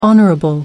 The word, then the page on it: hon. sec: American English